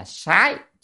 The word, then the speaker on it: sái: Chữ sát này ở đây có nghĩa là phải đọc âm là sái chứ không đọc sát là sai. Vừa rồi thì đang đọc sát đúng không, nó là theo thói quen thế, và tra từ điển cho thấy âm đọc là sái, sái là sai biệt.